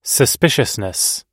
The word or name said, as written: suspiciousness